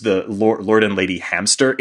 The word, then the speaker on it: Hamcester: Likewise, Hamcester is pronounced hamster, like the rodent, which I thought was like a very cute, funny thing.